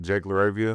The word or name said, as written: Jake LaRavia